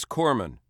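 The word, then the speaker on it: corpsman: It is Corman. You don't pronounce the S.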